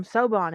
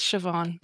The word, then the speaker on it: Siobhan: See, I always pronounce it Sobon in my head. Sobon? – Sobon. No, it's like, it's Siobhan, so. Yeah. I had to meet somebody named that to figure out how to pronounce it.